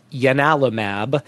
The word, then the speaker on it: ianalumab: So this is it's spelled like ianalumab, but it's actually pronounced ianalumab, named after the god Janus, the same god that gave its name to the Janus kinase, right?